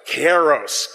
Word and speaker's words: kairos: I'm going to teach you a little Greek word here. It's the K word, but you have to figure out how to say it. And don't just mumble it. We need kairos. Kairos, kairos. You say tomato, I say tomato. We're all good.